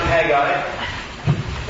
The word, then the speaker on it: Haggai: And I prefer to pronounce it Haggai.